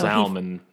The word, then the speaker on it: salmon: Salmon, actually. – It's nobody pronounces salmon. – I do, actually. – The L is silent.